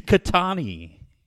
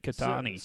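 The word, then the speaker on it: Ketanji: It's Catani. That's how you say it. Catani.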